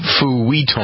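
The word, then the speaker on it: feuilleton: F-E-U-I-L-L-E. F-E-U-I-L-L-E-T-O-N. Roughly pronounced futon.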